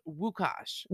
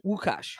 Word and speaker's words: Łukasz: I'm like, I don't know, because that's not how it's spelled. It's spelled L-U-K-A-S-Z. – Oh. – So I had to go to Google and translate. And so it's pronounced Wukash. – Wukash.